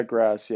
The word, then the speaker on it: Graz: Graz.